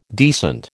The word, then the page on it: decent: American English